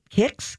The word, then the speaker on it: Kyx: It's called, I think you'd pronounce it Kicks, K Y X.